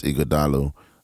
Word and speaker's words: Ighodalo: Evans Ighodalo, that is my name. – And that's spelled I-G-H-O-D-A-L-O.